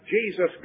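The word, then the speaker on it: Jesús: They pronounce it Jesus, by the way. Jesus, or Jesus, J-E-S-U-S. Here's Jesus Prado, Jesus Gonzalez. That's a very common name among Hispanic people. And again, it's pronounced Jesus, but it's spelled Jesus.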